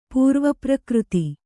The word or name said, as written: ಪೂರ್ವಪ್ರಕೃತಿ